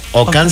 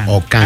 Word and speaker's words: Okan: Ocán se escribe con K. – O-K-A-N. – Ocán. – Así tal cual suena, pero con K.